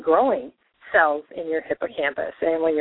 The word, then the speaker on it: hippocampus: It's hippocampus.